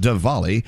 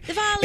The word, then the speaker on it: Diwali: You know, even though you may not be from uh, a heritage that uh, celebrates uh, Diwali, you know what? It's worth studying. Go do a, uh, a deep dive. It's spelled Diwali, but it's pronounced Diwali. – Diwali.